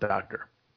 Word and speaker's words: Docter: Yeah, and written by, but it's pronounced Docter.